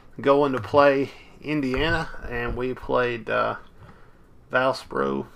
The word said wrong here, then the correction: Valparaiso: How do you say that, Tim? How's that? – Valparaiso. – Valparaiso.